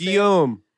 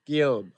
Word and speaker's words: Guillaume: How do you say Guillaume. – Guillaume.